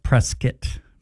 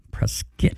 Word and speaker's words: Prescott: It's Prescott. Prescott. – How's it spelled? – Like Prescott.